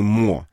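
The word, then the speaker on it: mots: Это так называемый МО. Это пишется МОТС, да, вот эти МО.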